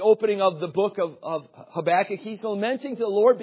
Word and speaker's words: Habakkuk: I don't know about you, but I love the little book called Habakkuk in the Bible. A lot of people don't understand that book Habakkuk. First of all, they have struggle maybe even just pronouncing the name, but it's Habakkuk.